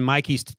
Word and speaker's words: Mike: I was just thinking in Mikey's case, and by the way, he spells it M-I-K-E, but he pronounces it Mikey, which I sort of like.